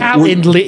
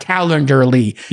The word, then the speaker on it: Calendly: I have the text message from 5 years ago when it said, hey, I'm raising And Calendly, is it? Wait. It's pronounced Calendly, not Calendarly.